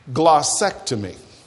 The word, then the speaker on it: glossectomy: Number one, I want you to ask God for a spiritual glossectomy. And I'm going to spell it for you. Somebody said, is that in the dictionary? Yeah, it's in the dictionary. G-L-O-S-S-E-C-T-O-M-Y. Glossectomy.